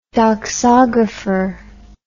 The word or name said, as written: doxography